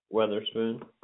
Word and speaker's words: Weatherspoon: Weatherspoon. – What Weatherson? – Yes, ma'am. – Weath. Spell it for me. – Like the weather outside and spoon like you eat with.